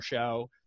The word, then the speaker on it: Shough: You know, looking at Oregon at 9, I think that shows a lot of faith in Tyler Shough. Am I pronouncing that last name right? I don't even know. – That's how I've said it.